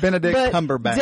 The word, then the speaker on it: Benedict Cumberbatch: I don't know if I said that right or not. – It's Benedict Cumberbatch.